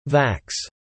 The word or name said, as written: vax